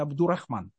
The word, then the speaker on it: Абдурахман: Ну, в русской традиции Абдуль Аль-Рахман редко произносится, поэтому по-русски его называют обычно Абдурахман.